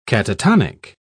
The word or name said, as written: catatonic